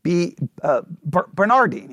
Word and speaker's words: Bernardine: Bernardine, yeah, okay, I'm saying it right. B-E-R-N-A-R-D-I-N-E. Bernardine, all right?